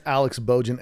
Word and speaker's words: Alex Boughen: And that's Alex Boughen, AlexBoughen. Is that right? – That's the one, yeah. – That's Boughen, how it's pronounced.